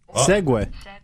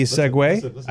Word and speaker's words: segue: I think segue is pronounced differently. – Hang on, right here. Where's the little thing where you could click on the pronunciation? Hang on, they've got audio. Of course, it's giving me the stupid little vehicle. Here we go. Dictionary, ready? Here we go. – Segway. S-E-G-U-E, Segway.